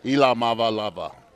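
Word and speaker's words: Iamaleava: Because he just tweeted out, by the way, yesterday, how to officially pronounce his name. Nico Iamalava. – Yeah, I'm going with Nico. – We're going to get this now. Iamalava. – Ava. Okay. Iamalava. Yeah.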